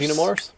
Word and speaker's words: Xenomorphs: The Xenomorphous... – The Xenomorphs? – Xenomorphs. – There we go.